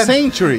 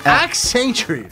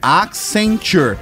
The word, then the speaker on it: Accenture: É sem o A, é Century. – Não, é Accenture. – Accenture. É, se escreve A-C-C-E-N-T. – T-U-R-E.